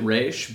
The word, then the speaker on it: Ra's: I know that Denny O'Neill, the creator of Ra's al Ghul, pronounced it Ra's, but he also did not give me a 100%. This is definitely the way. He's like, well, that's what I always said. Yeah. So I say Ra's because this was the first version of him I saw, but also we'll call him Ra's.